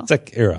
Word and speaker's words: Sekiro: Dan- Daniel Robson, the editor in chief of IGN Japan, tweeted us and said, "Oh, it's emphasized the first syllable. – That was that's the Hiro. like that. – Hiro. Sekiro.